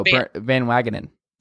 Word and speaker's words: Van Wagenen: And I also think it's amusing that when Brody Van Wagenen, how do you say his name? – Oh, Van Wagenen.